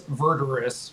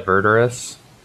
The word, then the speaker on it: Verdurous: I would assume that it's Verdurous. – Yeah, Verdurous. We got some Latin roots going on in the front. – If I just trick myself into thinking that I'm saying murderous, then I'll say it right every time.